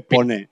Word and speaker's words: Pone: DJ Pon se escribe Pone, Pin. DJ Pone, no, ¿vale? Yo decía, pero se pronuncia Pon.